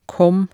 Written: kom

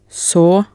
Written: saa

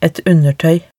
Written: et undertoey

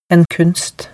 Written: en kunst

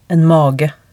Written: en mage

